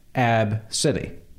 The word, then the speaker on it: ABCDE: This woman is mad because her child named Ab City, spelled A B C D E. She's upset that people don't pronounce it Ab City.